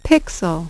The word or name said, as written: pixel